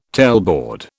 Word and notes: tailboard: British English

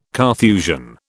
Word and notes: Carthusian: British English

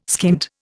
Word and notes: skint: Indian

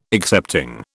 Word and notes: excepting: British English

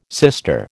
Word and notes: sister: American English

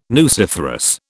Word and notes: nuciferous: British English

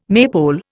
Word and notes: maypole: Indian

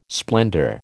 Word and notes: splendour: American English

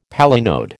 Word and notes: palinode: American English

